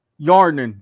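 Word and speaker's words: Hjarnan: But former LEC bot laner Hyarnen, who will be moving into the position of assistant coach for the 2022 LEC summer split. – Hyarnen. The H is silent.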